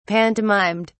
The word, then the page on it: pantomimed: British English